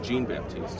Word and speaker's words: Jean-Baptiste: Stanley, I believe it's he says it's Jean-Baptiste, is going with the American pronunciation.